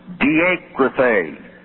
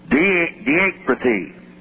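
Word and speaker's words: Diakrithē (διεκρίθη): In place of staggered, circle that word and draw a line to the margin and write the Greek word diakrithē. D-I-E-K-R-I-T-H. Long E, double E. Diakrithē.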